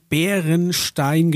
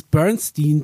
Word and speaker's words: Berenstain: Da geht es um so eine Bärenfamilie und die heißen, also die werden Bärenstein geschrieben, so wie ein deutsches Wort, aber die meisten haben das Berenstain Bears ausgesprochen. Und denen ist ein Leben lang nie aufgefallen, dass das nicht IE am Ende ist, sondern EI.